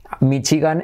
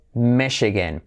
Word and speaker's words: Michigan: Number 26, Michigan. En inglés, Michigan. Tenemos I como E. Esta es una CH, pero realmente es una clave SH, ¿vale? Michigan. Y la A como una E. Michigan.